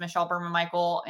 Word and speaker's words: Michelle Berman-Mikel: The reality, for those of you guys who are not familiar with me, again, my name is Michelle Berman-Mikel, and thank you, Curtis, for saying that right.